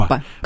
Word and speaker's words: Лаппа: Лаппа? – По-моему, все-таки Лаппа, угу. на первый слог ударения.